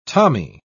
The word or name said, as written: tommy